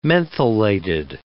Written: mentholated

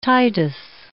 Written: Titus

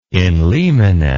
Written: in limine

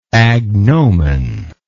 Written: agnominal

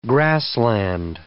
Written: grassland

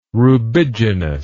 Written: rubiginous

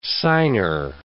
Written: signer

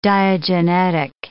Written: diagenetic

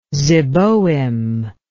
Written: zeboim

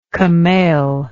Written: camail

